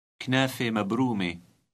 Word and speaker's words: كنافة مبرومة: Read number 13 and note the final silent T in both words pronounced E. – كنافة مبرومة.